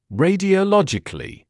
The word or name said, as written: radiologically